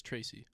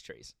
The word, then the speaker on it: Trace: Is that how you say his name? I thought it was Tracy. – No, it's Trace. – Trace? – It's Trace McSorley. – Like T-R-A-C-E? – Yeah, it's Trace.